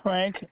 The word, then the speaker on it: Frank: The first name is Frank, F-R-A-N-K. – Okay. Last name? – Last name is Estrada, E-S-T-R-A-D-A.